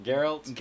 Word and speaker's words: Geralt: Geralt.